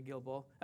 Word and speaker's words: Gilboa: That's how you say Gilboa. That's how I pronounce it.